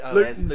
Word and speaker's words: Luton: We're in Luton, so there's a lot of official pronunciation there is Luton, by the way. Yeah, silent T, Luton. Luton.